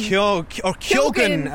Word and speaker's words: Keoghan: Keoghan, I think we're supposed to pronounce it. We say Keoghan in court.